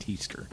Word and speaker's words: Tester: T-E-E? – Not T-E-A. – That's correct. – T-E-E? – Or no. – S-T-E-R? – It's actually tester, excuse me, but it's pronounced T-E-E. But it's T-E-S-T-E-R. – It's tester. – But it's pronounced teaster.